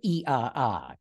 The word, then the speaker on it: IAA: Yeah, you know, they call that show the IAA, or as they pronounce it in Germany, the IAA.